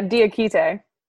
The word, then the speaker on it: Diakite: Yeah, Diakite. – Diakite.